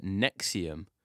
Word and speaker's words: NXIVM: We're going to jump into the first one, which is called Nexium. – Not heard of it. – No, that's good. That's good. So Nexium is spelt really weird. It's spelled NXIVM. I don't know why, but that's why it's spelt.